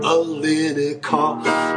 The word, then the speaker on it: Alinikoff: Come on, my last name can throw you way off. It's pronounced Alinikoff. – Alinikoff. – If you wonder where the name's from, it's all in a cough.